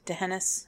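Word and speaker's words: D'Hanis: Also, DeHaenis, am I saying that right? – DeHaenis. – I can never say it right.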